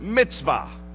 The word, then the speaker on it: mitzvah: In the Hebrew, it's pronounced mitzvah.